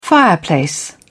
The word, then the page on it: fireplace: British English